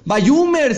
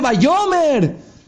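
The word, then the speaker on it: Bayomer (ויאמר): No se dice Bayumer, se dice Bayomer.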